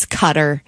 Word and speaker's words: Qatar: And then also on here was Qatar, which I say wrong as well. It's Cutter. Worcester sauce.